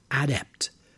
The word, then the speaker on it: adept: The word can also be used as a noun, but for the noun, the stress is on the first syllable. The person is now an adept, someone skilled in this particular profession.